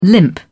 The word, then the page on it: limp: British English